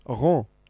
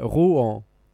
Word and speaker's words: Rouen: För det, jag trodde först att det skulle uttalas Rouen. – Ah, okay. – Men det är Rouen.